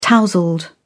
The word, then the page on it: tousled: British English